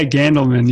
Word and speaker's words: Gandelman: Yeah, Gandelman, yeah.